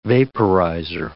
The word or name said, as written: vaporizer